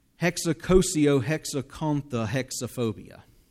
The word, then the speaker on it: Hexacosiohexacontahexaphobia: Hexacosiohexacontahexaphobia. Any clue what that was? I was not stuttering. It's the fear of the number 666. Hexacosiohexacontahexaphobia.